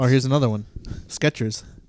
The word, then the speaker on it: Skechers: No T in it. – Yeah. Wow. Yeah. I didn't think it was a T in it. – I pronounce a T. Sketch. – Yeah. Sketchers. Sketch. – So Skechers.